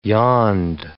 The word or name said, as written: yond